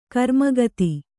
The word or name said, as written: ಕರುಮಗತಿ